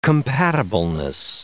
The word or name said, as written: compatibleness